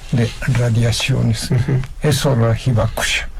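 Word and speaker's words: Hibakusha (被爆者): Hibakusha.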